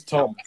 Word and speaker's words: Thom: That's spelled T-H-O-M, but I'm assuming you say it Tom. – I think it's Tom.